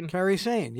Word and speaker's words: Kairi Sane: I think I'm pronouncing it right. Kairi Sane? – Kairi Sane, yes.